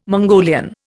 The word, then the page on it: Mongolian: Indian